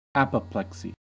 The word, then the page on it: apoplexy: American English